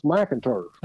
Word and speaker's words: McInturf: And he said, No, it's pronounced McInturf. Ah. Like MAC.